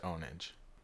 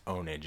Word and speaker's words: Pwnage: It's spelled Pwnage, but it was pronounced Ownage. – With a P? – With a P. It was spelled Pwnage, but it was pronounced Ownage.